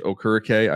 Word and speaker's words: Okereke: And you think about what they have with the Colts now, which I pronounce it Bobby Okereke for a long time, but now I guess it's Okereke. I've been getting it wrong.